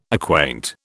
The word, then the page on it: acquaint: British English